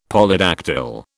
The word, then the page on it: Polydactyl: British English